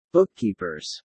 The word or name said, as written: bookkeepers